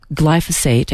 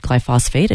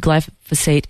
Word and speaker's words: glyphosate: We had talked last week about glyphosate, and I mispronounced it glyphosate, and excuse me for that.